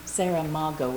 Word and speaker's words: Saramago: It's spelled Saragamo. Saramago would be the phonetic spelling pronunciation.